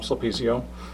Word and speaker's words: Sulpizio: Sulpizio, Tom Sulpizio.